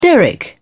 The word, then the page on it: derrick: British English